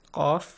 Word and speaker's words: Qaf: Qaf. – Qa, qul. – Yes. – It's different from ka.